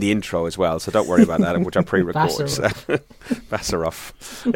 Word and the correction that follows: Borisov: Yeah, Borisov. I've actually written it down as Borisov as well, so you know.